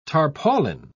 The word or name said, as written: tarpaulin